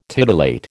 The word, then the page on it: titillate: American English